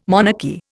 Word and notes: monarchy: Indian

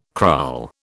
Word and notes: kraal: British English

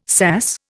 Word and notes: SAS: Indian

